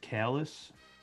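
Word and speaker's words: Kalas: Actually, it's pronounced Kalis.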